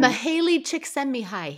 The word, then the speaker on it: Csikszentmihalyi: Mihaly Csikszentmihalyi.